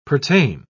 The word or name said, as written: pertain